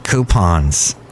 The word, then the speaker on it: coupon: That's right, coupons. And that's how you say it, coupon. Like gray poupon, coupon.